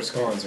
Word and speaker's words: scone: They're scones, not scones. – I don't know what the fuck that means. – Scone. – How do you spell it? – S-C-O-N-E. – Scone. – Well, I mean, maybe. – I'm just not saying it with an English accent.